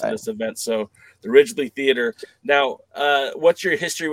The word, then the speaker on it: Ridglea: You kick it off Thursday night at the help me pronounce it because I don't want Ridglea to say it Theater. – Ridglea, okay. – You got it.